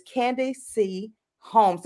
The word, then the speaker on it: Candace Holmes: And my first guest of the season is Candace Holmes.